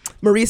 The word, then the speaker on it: Marie: If you don't know how to spell, Marie spelled M-A-R-I-E. – Uh-huh. – Because people keep spelling it with a Y. – Oh. – Or they spell it the right way and they call me Mary.